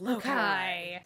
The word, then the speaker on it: Loki: It's spelled Loki, but it's pronounced Lokai.